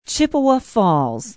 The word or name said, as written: chippewa falls